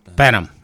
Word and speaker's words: Benham: Benham. Okay, rhymes with venom.